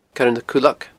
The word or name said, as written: Coire nan Cuileag